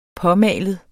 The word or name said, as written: påmalet